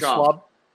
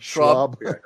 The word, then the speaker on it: Schaub: Brendan Schaub. Schwab. – Yeah. – Schwab. – Yeah. Schwab. Yes.